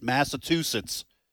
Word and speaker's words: Massachusetts: I do get a little tired of people pronouncing it Massachusetts. – That's what everybody says. It's with a chew, right? – It's like chew. Yeah, chew. Massachusetts.